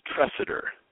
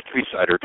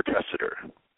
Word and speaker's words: Tresidder: It's Tresidder. – Tresidder. – So you're close. Not Tresidder, Tresidder.